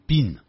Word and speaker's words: been: 中文翻成呢一直都在，这种一直都在呢，它的这个造句法就是主语后头用 have 或者是 has 再加 been， B-E-E-N，这口边念成 been 啊，念成 been， been it 的音啊，然后再放现在分词。